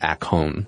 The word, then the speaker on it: akon: So a whetstone is apparently pronounced akon in Greek.